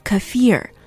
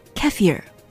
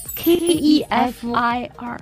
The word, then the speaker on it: kefir: 在美国，我们听到的大多数的发音是 kefir，可是专家说了，这样的发音是不正确的，正确的发音应该是 kefir，把重音放到 f i r 上面，kefir。那么也有人保留了它原本俄罗斯语的发音 kefir，所以这个单词虽然拼起来并不困难，k e f i r，但是它正确的发音到底是什么？很多美国本土的人都不太清楚。